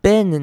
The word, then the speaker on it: been: 这边 been 呢，B-E-E-N，我们虽然它是 E-E，应该要念成母音 E 的念法，但是这边比较特别，念成短母音 I. Been 这个字都是念成 been。